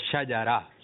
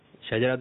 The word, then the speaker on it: شجرہ: درست تلفظ ہے شاجارہ. کیا ہے؟ یعنی شین پر زبر ہے, جیم پر بھی زبر ہے اور را پر بھی زبر ہے. ٹھیک ہے شاجارہ. کچھ لوگ کہتے ہیں شجرہ, شجرہ شریف, شجرہ شریف. تو یہ تلفظ صحیح نہیں ہے.